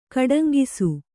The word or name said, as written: ಕಡಂಗಿಸು